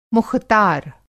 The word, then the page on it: मुख़तार: مُختار